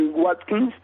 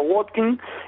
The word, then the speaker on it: Watkins: Ollie Watkins, también aquí le dicen la W, la pronuncian como B, por eso le digo Ollie Watkins, pero en inglés sería ser Ollie Watkins o Watkins,